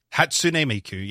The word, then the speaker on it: Hatsune Miku: Hatsune Miku, yep.